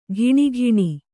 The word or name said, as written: ಘಿಣಿಘಿಣಿ